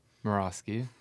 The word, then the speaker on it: Moroski: Moroski.